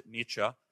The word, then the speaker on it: Nietzsche: Just learn how to pronounce it, Nietzsche.